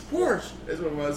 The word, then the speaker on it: Porsche: I'm like, it's a Porsche. – No, it's Porsche. – What? – Yes, that's how you say it. It's Porsche. – Is it Porsche? – Yes.